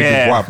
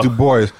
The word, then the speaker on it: Du Bois: Okay, I was just wondering if she, because people do change pronunciations, W.E.B. Du Bois, you know, it's supposed to be Du Bois, but he does Du Bois.